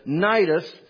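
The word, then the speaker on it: Cnidus: spelled with a C, but Nidus.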